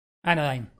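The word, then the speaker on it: Anodyne: Anodine, ahí está.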